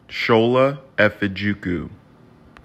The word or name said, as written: Tsola Efejuku